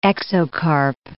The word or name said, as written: exocarp